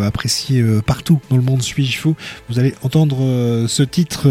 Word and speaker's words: S8JFOU: Et on va commencer avec Suis-je Fou. Alors ça s'écrit S-8-J-F-O-U.